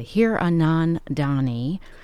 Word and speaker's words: Hiranandani: Acclaimed author Veera, I'm going to pronounce her name correctly, Hiranandani, has a new book out called AMIL AND THE AFTER.